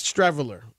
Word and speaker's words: Streveler: And you remember the dude, Stevel. How do you say his name? Streveler? Yes, Streveler will be the backup.